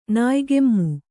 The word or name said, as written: ನಾಯಿಕೆಮ್ಮು